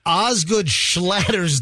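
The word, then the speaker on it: Osgood-Schlatter: Hold on, it's Osgood Slaughter's. – It's Oscar Slaughter's. – Oscar Slaughter. – No, it's Fester just brought it up on Google. – Oscar Slaughter's disease. – Osgood Slaughter's disease.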